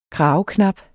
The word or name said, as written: kraveknap